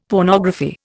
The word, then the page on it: pornography: Indian